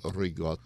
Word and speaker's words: ricotta: There are many different ways you can make ricotta. – Yeah. Did I say that right? – Ricotta.